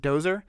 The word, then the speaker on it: Dowser: He's Father Everett in Daredevil and Dowser in Brazil. Is that right, Dowser? – I think in the movie they pronounce it Dozer.